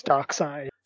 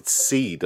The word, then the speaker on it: Darkseid: I'm pretty sure it's Darkseid. – Yeah, I've always pronounced it Darkseid, but they spell it S-E-I-D, which some people have said is seed. I'm like, no.